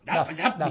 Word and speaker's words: Dove: Dab, mujeres normales, ¿no? Dab con V. – Dove. ¿Vale? Sí. Bueno, pues bien, pues Dove, o Dab, como se pronuncia, qué demonios.